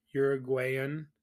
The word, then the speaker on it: Uruguayan: So another tough match because, again, I think that's going to be a lot of your Uruguayan. Is that – am I saying that right? – Yep.